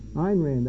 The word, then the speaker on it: Ayn Rand: Ayn Rand, actually, is the way to pronounce it.